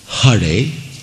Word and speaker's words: Hare: Well, in the Sanskrit mantra, the correct pronunciation is Hare with a ha and Rama.